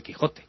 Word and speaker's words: Quijote: todo el mundo sabe que el Quijote es el Quijote, con el sonido J, sin más. Y es lo mismo que estamos explicando aquí, ¿verdad? – Y antiguamente se pronunciaba Quixote, claro. Y cambió la pronunciación a Quijote.